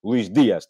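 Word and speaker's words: Luis Díaz: Peço desculpa, não, não é Dias, é Dias. Luís Dias, tenho que dizer 100 vezes Luís Dias para não me enganar mais na, na pronúncia correta do nome do, do avançado colombiano do Porto.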